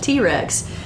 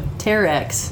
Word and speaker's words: Terex: I'm a lifelong learner, and I would call it T-Rex, and they would just laugh at me, but I didn't realize I was pronouncing it incorrectly. So Terex.